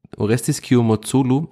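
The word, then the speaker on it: Orestis Kiomourtzoglou: Nämlich äh, ein Dreikampf im Strafraum zwischen Ali Loon, Orestis Kiyomotsulu, so wird er übrigens ausgesprochen, liebe Kollegen von Kadepp, die sich sehr schwer getan haben mit dem Namen, die Nummer 13 von 4, Zitat, und ähm, Florian Hübner.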